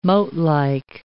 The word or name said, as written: moatlike